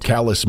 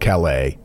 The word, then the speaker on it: Calais: Calais, Maine, not Calais.